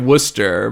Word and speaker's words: Worcester: It's spelled, you say it Worcester, but it's spelled like Worcestershire.